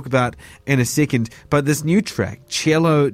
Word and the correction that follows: Cielo: Drive, am I saying that right? Cello? – Cielo.